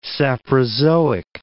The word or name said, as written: saprozoic